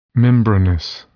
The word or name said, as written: membranous